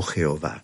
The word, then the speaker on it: Jehová: Un nombre tan sagrado para el pueblo que ni siquiera se atrevían a pronunciarlo, y que se trataba, sin duda, del tetragrama o conjunto de cuatro consonantes del nombre de Dios de Israel, YHWH, pronunciado Yahvé o Jehová.